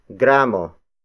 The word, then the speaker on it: gramo: For weight of objects, especially food products, the metric system is applied, The basic unit of weight is gramo. Gramo. Gramo. Gramo is spelled as G-R-A-M-O.